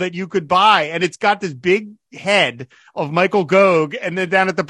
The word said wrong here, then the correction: Gough: It's pronounced Goff. – Oh, is it Goff? – Goff, Michael Goff, yeah.